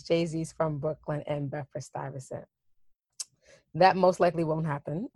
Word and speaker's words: Stuyvesant: Your dream scenario, some artist writes a song about, I want to make sure I'm saying this right, Stuyvesant, a different emphasis, Stuyvesant champagne.